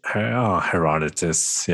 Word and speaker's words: Herodotus: Herodotus, I don't know how to say it in English. – Herodotus, yeah.